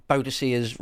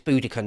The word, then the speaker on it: Boadicea: Talk all about Boadicea's revolt. I pronounce it Boadicea. I've got no truck with all this Boudica nonsense. Oh no, I was raised as Boadicea.